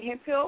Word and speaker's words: Hemphill: But, you know, for more information about me, contact me on Google, you know what I'm saying, keyword, Blaine Hemphill, or you can also type in Blaine the Model, and you spell that B-L-A-Y-N-E, and my last name is H-E-M-P-H-I-L-L.